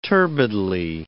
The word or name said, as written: turbidly